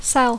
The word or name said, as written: sell